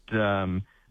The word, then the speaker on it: Cherbourg: So with those drier and warmer conditions, there's a few pockets of higher fire danger in the area around Cherbourg. Tell me if I'm pronouncing that right. – That's correct. – Cherbourg, right. Had a crack at pronouncing it the French way, but okay, Cherbourg, yep.